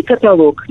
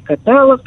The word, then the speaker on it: каталог: Или каталог, несчастный каталог, который то и дело коверкует и произносят каталог. Никаких вариантов. Каталог.